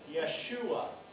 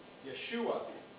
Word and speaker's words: Yeshua: If you spell Joshua, if you phonetically pronounce Joshua from Hebrew, you would actually pronounce it Yeshua. Yeshua.